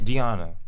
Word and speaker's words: Deonna: Okay, how do you pronounce your name? Because everyone's like, wait. – How do you pronounce it? – Deonna. – That's how you pronounce it. – Okay. I said Donna, but I forgot the E.